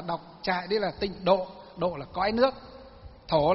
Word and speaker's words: tịnh độ: Nguyên và âm của nó đọc đúng theo chữ Hán. Chứ vay nghe đen là tịnh thổ tông. Đấy, chúng ta đọc là tịnh độ tông.